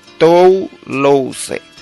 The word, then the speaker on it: Toulouse: Puedes buscar eso en internet, anótalo allí, Sínodo de Toulouse, así como se pronuncia, usa la S en lugar de la C, Toulouse.